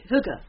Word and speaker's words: Hygge: Hygge. – Hygge.